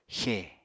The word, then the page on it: xè: Apatani